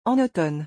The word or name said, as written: en automne